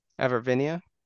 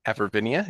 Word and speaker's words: Avervinia: He's picked up envelop, which is a rule previously seen on monsters like Globicus and Avarinia. – Avervinia. – Avervinia, is that how we pronounce it? Okay, now we know.